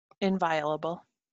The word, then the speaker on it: inviolable: How do you pronounce that word? – Inviolable. You did it right.